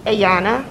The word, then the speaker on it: Ayanna: A-Y-A-N-N-A. – How do you say your first name? – Ayana. Yes. – Davis? – No, Davis is my daughter. I'm Carr. C-A-R-R.